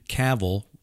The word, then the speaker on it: Cavill: The Henry Cavill, rhymes with travel, news that I had, the rumors turned out to be unfounded.